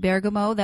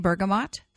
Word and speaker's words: bergamot: And I do pronounce it bergamot. That was the way I learned to pronounce it when I first started this modality in Italy in 1991. And it stuck. And that's sort of a hallmark of my students. You know they're my students when they pronounce it that way. If you say bergamot, that's fine. I think there are both pronunciations are just fine.